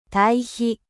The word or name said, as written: compost